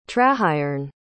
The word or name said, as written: trahaearn